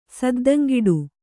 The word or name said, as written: ಸದ್ದಂಗಿಡು